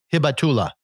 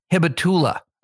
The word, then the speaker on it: Hibatullah: We have Habutala Akunzada, who is, I hope I'm pronouncing that right. – No. Hibatula. – Hibatula.